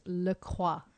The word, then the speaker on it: LaCroix: Yes, in French, it's LaCroix. – LaCroix. – LaCroix. – But people say LaCroix, but I think it's LaCroix. – We say LaCroix.